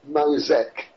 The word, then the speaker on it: Mosec: And I opened my eyes after it started writing again, and a word came up on the page of M-O-S-E-C, Mosec, M-O-S-E-C, I pronounce it, Mosec.